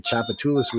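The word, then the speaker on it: Tchoupitoulas: Just like you say Chapatulas, which is not spelled like Chapatulas. It starts with a T, people.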